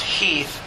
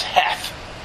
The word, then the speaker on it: Heth: So Pettigrew goes and reports to his commanders, General Henry Heath, pronounced Heath, although I've always thought it was Heth, because it's spelled that way, H-E-T-H. But I watched a video about Gettysburg this week, and they kept pronouncing it Heath.